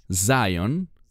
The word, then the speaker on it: Zion: Bueno, la palabra Sion, que en inglés se pronuncia Zion, esa palabra es una palabra de reino.